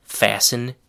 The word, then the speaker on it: fasten: The next collocation is fasten your seatbelt. The word fasten is spelled F-A-S-T-E-N. The letter T in fasten is silent, kind of like in the word listen.